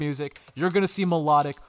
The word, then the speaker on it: Mlotik: And it's spelled M-L-O-T-I-K. And it's pronounced Melodic.